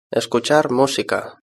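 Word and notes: escuchar musica: Spanish (Spain)

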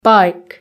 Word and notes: bike: British English

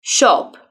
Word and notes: shop: British English

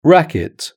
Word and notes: racket: British English

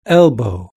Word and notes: elbow: British English

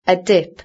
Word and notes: a dip: British English

